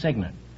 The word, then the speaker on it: cygnet: It's spelled C-Y-G-N-E-T, but it's pronounced signet.